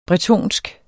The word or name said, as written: bretonsk